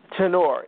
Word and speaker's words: Tenor: Tenor. It's Italian. – Tenor. – T-E-N-O-R. That last E is silent. It throws everybody off.